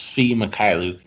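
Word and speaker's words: Svi Mykhailiuk: So a guy like Sadiq Bey or even Sfi Mikhailiuk, I took a long time to master that pronunciation, but it's Sfi Mikhailiuk.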